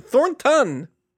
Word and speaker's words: Thornton: It's a ton. Thornton. – Anyway, go ahead. – The N in the middle, just in case you're trying to spell it.